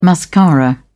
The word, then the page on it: mascara: British English